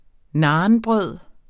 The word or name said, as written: nanbrød